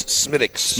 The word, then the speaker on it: Smithwick's: Reads like Smithwick's. – Pronunciation is... – But it's Smittix. – Smittix. You'll make some people angry if you pronounce it otherwise.